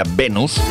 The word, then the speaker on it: Venus: Eh, pero bueno, hay que escoger la versión, la mejor versión, y una de las grandes versiones del tema Venus, que sería Venus en español, pero en inglés lo pronuncian así, dicen Venus, es la que hizo en su momento Bananarama.